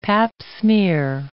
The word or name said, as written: Pap smear